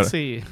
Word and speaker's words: Xaxi: okei, kuidas sa hääldad siis seda nime lõpuks? – Xaxi, Xaxi see on, see on koera nimi. see on koer. Saksi. Saksi.